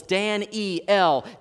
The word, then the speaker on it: Daniel: Daniel has three syllables, Dan-E-L.